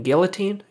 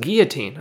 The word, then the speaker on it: guillotine: I noticed a lot of these, the announcers here on the UFC, they pronounce guillotine, Gill-A-Tine, you know? I blame Joe Rogan for that because he says guillotine also, and Eddie Bravo also says guillotine. Guys, it's pronounced guillotine. I don't know. It's a French word.